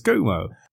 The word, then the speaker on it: Cuomo: The correct pronunciation is Cuomo.